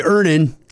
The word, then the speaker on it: Earnin: Earnin. E-A-R-N-I-N is pronounced Earnin. I don't know how else you would pronounce it, but they really want it clear that that's how to pronounce it.